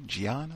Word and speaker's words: Ghian: Chicago Police Board president Gian Foreman, hope that's how you say it, G-H-I-A-N, Gian, I think that's it,